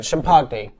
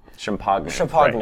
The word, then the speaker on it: Champaign: Pronouncing that correctly. – Urbana-Champaign. Champagne. – Champagne. Right, yes. My mistake.